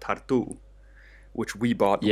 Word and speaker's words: Tartu: But it's called tartu, T-A-R-T-U, with an accent on the U.